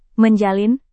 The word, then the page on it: menjalin: Indonesian